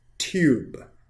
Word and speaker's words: tube: 那在这里边，u 这个 e 它不发音，那 OK，我们就可以看到这个 u 就发的是 tube，t u t tube tube OK，and next one，take a look at this one is book。